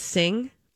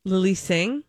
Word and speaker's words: Singh: Is her last name pronounced Singh? Lily Singh? – Yeah. – Okay, thank you.